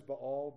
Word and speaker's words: Baal: They forsook the Lord and served Baal, that's actually how you pronounce that, is Baal, but we always say Baal.